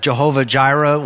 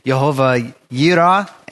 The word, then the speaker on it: Jehovah Jireh: And one of the things that we'll be looking at is this, one of the names that you probably have heard, people have pronounce it Jehovah Jireh, well, or more like Jehovah Yireh, and Yireh is the key thing that we'll be looking at throughout this, and it's a key aspect of it.